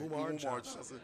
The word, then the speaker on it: Umar Johnson: Yeah, I mean, that's definitely a huge issue as far as, like, you know, as I heard Brother Mamor Johnson, I think I said his name right. – Umar Johnson. Yes.